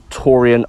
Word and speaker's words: Taurean: You can catch me on Facebook at Taurean O'Sullivan. My name is spelled T-A-U-R-E-A-N. O'Sullivan is O-S-U-L-L-I-V-A-N.